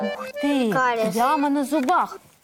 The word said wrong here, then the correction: кариес: Вот, называется кариес, правильно.